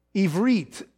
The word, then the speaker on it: Ivrit: By the way, just so that doesn't go, Ivrit is how you say Hebrew in Hebrew.